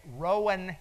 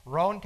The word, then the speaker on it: Rowan: Kim is the clerk of courts in Rowan County, Kentucky, and I taught in Kentucky not long ago, and I was calling it Rowan County, Kentucky, and they said, no, it's all one syllable, Rowan County, Kentucky.